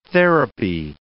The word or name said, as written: therapy